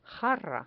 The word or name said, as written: jarra